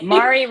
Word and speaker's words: Mari: Am I pronouncing Mari correctly? – Yes. – Okay. – Mari rhymes with sorry.